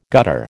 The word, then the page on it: gutter: American English